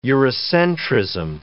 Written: Eurocentrism

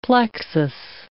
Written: plexus

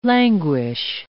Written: languish

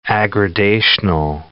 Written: aggradational

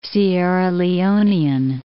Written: Sierra Leonean

